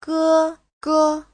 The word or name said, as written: ge1ge5